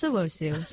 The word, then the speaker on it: Owusu: Yes, it's Genesis Owusu. – Okay. Well, we have the previous music director. He actually knows music, so Genesis, I'm a poser. – Genesis Owusu.